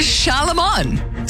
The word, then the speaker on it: Charlemagne: It's Charlemagne. Say it right.